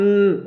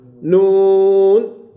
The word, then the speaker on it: ن: عندنا الحروف المقطعة أو الحروف المذكورة في أوائل الصور، هذه الحروف طريقة كتابتها تكتب حرف، تكتب حرفا، أما النطق لا تقرأ حرفا وإنما تقرأ اسما للحرف، تقرأ اسما للحرف، يعني كنكتب النون ولا نقرأ ن، وإنما نقرأ نون، تكتب صاد حرف واحد ولكن تقرأ باسمها، دي خاصة لهذه الحرف، خاصة لهذه الحرف.